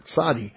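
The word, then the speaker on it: tzadi (צדי): The first syllable is where the emphasis is in this letter, tzadi.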